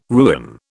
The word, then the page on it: ruin: British English